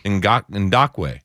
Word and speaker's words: Ngakoue: You're getting rid of, I can't always say his name, Ngakoue. – Ngakoue.